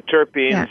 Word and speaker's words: terpenes: So, Dr. Ingram, before the break, you were going to get into explaining terpenes. Did I say that right? – Yes, the terpenes, yeah, along with the cannabinoids.